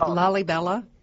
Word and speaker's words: Lalibela: It's called Lalibela. – Lalibela, yeah. It's spelled L-A-L-I-B-E-L-A.